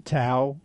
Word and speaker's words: Tao: So this movement, it's the Tao, T-A-O, the Tao, but it's pronounced Tao.